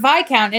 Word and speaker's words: Viscount: Like, I've been wondering, I mean, the word Viscount in and of itself, why does it have a silent S?